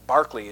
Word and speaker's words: Berkeley: He is the major figure for philosophy between Locke and Berkeley, or Berkeley is how they say his name.